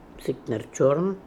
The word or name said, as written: Sikt ner chorn